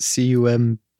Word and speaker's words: Cumberbatch: I don't think that's, is it Cumberbatch? – Yeah. – Oh, really? – You got it.